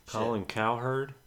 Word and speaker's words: Cowherd: Colin Cowherd.